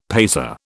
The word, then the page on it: paisa: British English